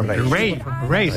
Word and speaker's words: Raye: Ray. Se escribe Raye. Raye, con Raye, ya Raye.